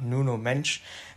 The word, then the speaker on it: Nuno Mendes: Und zwar handelt es sich dabei um Nuno Mendes oder Nuno Mensch, wie man ihn manche auch aussprechen.